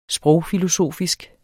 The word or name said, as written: sprogfilosofisk